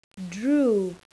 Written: drew

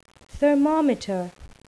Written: thermometer

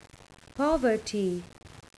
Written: poverty

